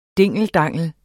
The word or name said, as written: dingeldangel